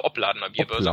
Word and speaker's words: Opladener Bierbörse: Also die ähm, in Leverkusen und zwar die Opladener Bierbörse, oder? – Genau, mit der Betonung auf dem Ob, also Obladener Bierbörse.